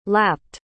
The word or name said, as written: lapped